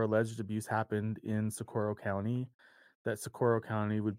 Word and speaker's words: Socorro: And I know I'm going to say it wrong again, Sequoia? – Socorro. – Socorro.